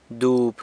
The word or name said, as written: dob